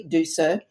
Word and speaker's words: Desir: It's spelt interestingly, but it's said very easily, Desir.